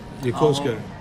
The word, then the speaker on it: Yokosuka: Or, is if you want to pronounce it like some Americans do, Yokosuka. But it's not Yokosuka.